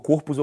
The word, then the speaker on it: corpos: As meninas de 12 anos, elas acabam tendo corpos, é, corpos, desculpa, corpos eu falei errado. É, é, isso aí é o plural metaf metafônico, tá? Quando você, quando você coloca uma palavra no plural, é, no singular você fala corpo, mas no plural você não pode falar corpos, tá errado.